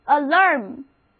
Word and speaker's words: alarm: Alarm，读作 alarm，A-L-A-R-M， alarm，因为字母组合 A-R 挪到中间去了，发音变成了 a，同时重音也后移了。